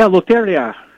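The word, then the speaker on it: Lotería: Yeah, Loteria.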